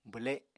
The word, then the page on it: bwle: apatani